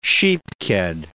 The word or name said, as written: sheep ked